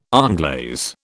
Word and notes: onglaze: British English

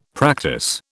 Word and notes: practice: British English

